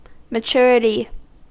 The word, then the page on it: maturity: American English